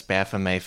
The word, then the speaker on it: Baphomet: Yeah, and the origin of this Baphomet figure, and I'm going to use the pronunciation Baphomet because it feels more French, and this artist is French.